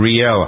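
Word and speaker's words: Rielle: John Edwards and his mistress, is it Riley Hunter? I never pronounced it. – Riel Hunter.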